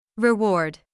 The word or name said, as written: reward